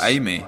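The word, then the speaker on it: Aimee: And also, you hung out because Anime Is that how you pronounce her name? Anime of the Interrupters. – Oh, Amy.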